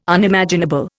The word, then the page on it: Unimaginable: Indian